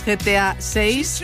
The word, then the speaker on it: GTA 6: Y hoy tenemos una cita para los amantes de los videojuegos, GTA 6. No sé si se dice así, me corriges. – Así se dice.